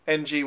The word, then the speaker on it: Ng: She was East Asian name, NG, which I always struggle with pronouncing, which is Ng.